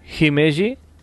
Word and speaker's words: Himeji: uno de los castillos ah, más famosos de Japón es el castillo de Himeji.